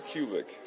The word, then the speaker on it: Kulick: Kulik, sorry, K-U-L-I-C-K. It's hard to pronounce just because Polish. And then truncated, it used to be Kulikowski.